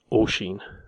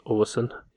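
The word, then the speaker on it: Oisín: I didn't know what name it was, and I downloaded the PDF of the book to find out that the spelling was O-I-S-I for the N, and that is pronounced O-Sheen. It's not pronounced O-Sin, it's O-Sheen, O-Sheen.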